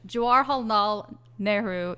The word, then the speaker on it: Jawaharlal Nehru: Jawaharlal Nehru University in New Delhi, also in New Delhi, where again there were student protests.